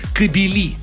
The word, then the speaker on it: кабели: Если множное, там будет «кабели». А вот кобеля, это только я своего кобеля выгулил там с утра пораньше. Вот кобеля, тогда может быть такое ударение. Но правильно, если мы говорим про электрические сети, кабели.